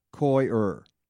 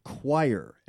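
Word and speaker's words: coir: But our staff, our crack staff, tweeted, Koyer is pronounced K-O-Y hyphen U-R, Koyer. Two syllables, according to most authoritative sources. The second most recommended is like choir, as C-H-O-I-R.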